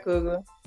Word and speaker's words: Coogler: It was written, was directed by a person of color, Ryan Coogler. Is that how to pronounce his last name? – Yeah, Ryan Coogler.